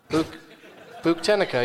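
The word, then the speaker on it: Buktenica: Buchtenica, yeah, that's how you pronounce it.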